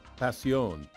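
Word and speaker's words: Pasión: It's from Murviedro Passion, P A S I O N, with an uh, emphasis on the O, and so it's passion.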